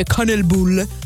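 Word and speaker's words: kanelbulle: Molto molto mangiato in questo momento è il cinnamon roll, che in svedese sarebbe kanelbull, così si dovrebbe pronunciare.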